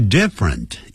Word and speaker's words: different: 这个 different 也是两个音节不要念成 different different different 好特别要这样记